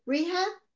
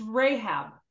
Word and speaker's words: Rahab: Do you pronounce it the same, rehab? – Yeah. – No, it's Rahab.